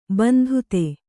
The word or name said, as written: ಬಂಧುತೆ